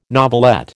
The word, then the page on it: novelette: American English